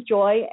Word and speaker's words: Joy: and so sometimes I'll say my name's Joy, and people say, they call me Joey. I'm like, no, the word joy, like happiness, you know?